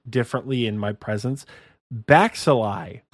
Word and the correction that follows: Baxley: Baxil? I'm not sure. Michael's laughing because he knows I said it wrong. Baxley. Damn it, it is Baxley.